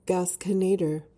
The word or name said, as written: gasconader